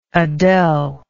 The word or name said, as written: adele